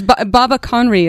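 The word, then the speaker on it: Baba Connridh: Yeah, it's Baba Conry is how it's pronounced, but it's B-A-B-A and then space C-O-N-N-R-I-D-H. So it's the Scottish spellings of Baba Conry.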